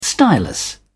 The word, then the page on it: stylus: British English